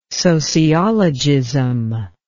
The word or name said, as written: sociologism